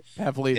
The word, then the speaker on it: Pavlidis: Am I saying that wrong? – Pavlidis. Yeah, Pavlidis. Yeah.